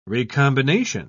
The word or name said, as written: recombination